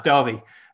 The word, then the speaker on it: Derby: I promise you, it's pronounced Derby, as I tried to tell them when I was in Kentucky a few years ago, but they weren't having it.